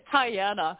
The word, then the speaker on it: Tijana: Tijana. It's kind of like Diana, but with a T, just spelled like way, way messed up.